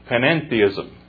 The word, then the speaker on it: panentheism: Panentheism. You know, here we have polytheism, we have pantheism, we have deism. Now, panentheism. It's spelled like pantheism, only has the E-N in it. Panentheism.